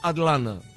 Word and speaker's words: Atlanta: Acá si lo querés pronunciar bien, tenés que decir Atlanta. – Atlanta. – Como consumiéndote el ta, Atlanta. – Claro, casi que es un na, Atlanta.